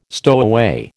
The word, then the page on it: stowaway: American English